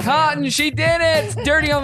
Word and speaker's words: KOTN: This is called Cotton, but it's spelled K-O-T-N. Cotton.